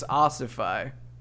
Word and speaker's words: ossify: Okay, I pronounced it completely wrong. Jeez. It's ossify.